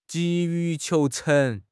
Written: 急于求成

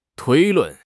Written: 推论

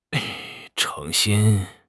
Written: 成心

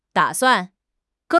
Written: da3 suan4

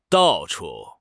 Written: dao4 chu4

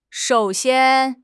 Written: shou3 xian1

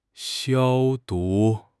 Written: 消毒

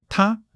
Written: 她

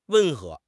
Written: 温和

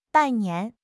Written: 拜年

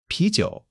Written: pi2 jiu3

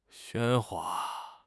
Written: 喧哗

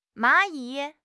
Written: 蚂蚁

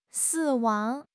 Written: 死亡

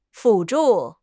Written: 辅助

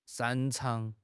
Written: 擅长

